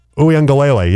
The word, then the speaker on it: Uiagalelei: DJ Uiagalelei? – Uiagalelei, yes. – Yeah, do you think Dave Logan retires? Uiagalelei throws over the middle to Ogbonam.